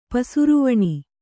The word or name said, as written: ಪಸುರುವಣಿ